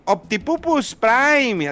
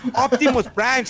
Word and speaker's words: Optimus Prime: Pero que vayas a una función y lleven a los niños llorando, gritando, mire mamá, Optipupus Prime, así que no, cállate niño, así no se dice, Optimus Prime, señora, lleve a su hijo a una escuela de inglés, que sepa pronunciar.